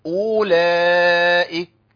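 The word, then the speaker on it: أولئك: ما ينفعش اقول اولئك، اولئك الواو اللي هي مرسومه دي بعد الهمزه لا تنطق.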